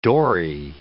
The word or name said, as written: dory